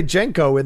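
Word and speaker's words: Genco: But then I remember they do say Genko in the movie a number of times.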